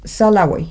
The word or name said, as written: salawey